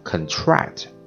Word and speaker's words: contract: 啊，当然做动词的时候啊，这个词要读成 contract 啊。那么这个是符合英文当中啊重音位置名前动后这样的一个规律。